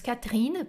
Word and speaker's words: Catherine: Patrick e Catherine, que se escreve Catherine, né? Em francês geralmente tem o H aqui, ó mas nós dizemos Catrina, porque esse aqui é quase que não existe, é como se ele fosse, como se fosse a assim, sua Catrina, tá?